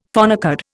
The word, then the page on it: phonecard: Indian